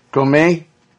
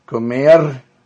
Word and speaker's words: comer: Comer. – Comer? Comer. You got to have that roll on there.